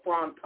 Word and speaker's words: fantods: As a word I learned today, it's called it's spelled FANTODS. It's called frontage. It means jittery, anxiousness. – FAN. – No, that's FAN, F as in Frank, AN as in Nia, TODS, D as in David, S.